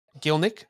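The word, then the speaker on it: Gielnik: Emily Galenik notches the winner in 90+3. – Gilnick. – Thank you. I think I said it wrong last week too. Why didn't you guys pick me up on this last week? Gilnick. Sorry, Emily. My bad.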